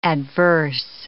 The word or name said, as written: adverse